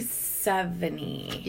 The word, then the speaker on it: Sevigny: How do you say her last name? – Chloe Sevigny. – Yeah. – Sevigny? Sevigny.